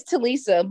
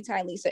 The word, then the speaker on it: Tylisa: It's Talisa, but everybody call me Tylisa. It's fine because it's spelled T-Y.